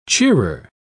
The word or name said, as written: cheerer